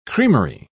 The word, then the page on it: creamery: British English